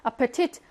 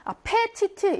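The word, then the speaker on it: appetite: 아파티트, 아파티트, 아페티트 이렇게 하지 않고요. 아파티트 강세가 뒤에 오니까 얘는 약해집니다.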